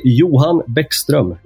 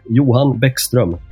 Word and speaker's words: Johan Bäckström: In Swedish, I say Johan Bäckström, Johan Bäckström.